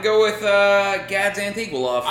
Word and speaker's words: Antigulov: It's Antigulog. Antigulov.